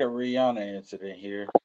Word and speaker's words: Rihanna: It's pronounced uh, Rihanna.